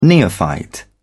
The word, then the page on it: neophyte: British English